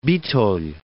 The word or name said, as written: Bitolj